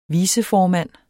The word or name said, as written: viceformand